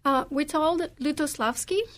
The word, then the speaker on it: Witold Lutosławski: Witold Lutosławski.